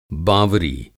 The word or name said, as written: बावरी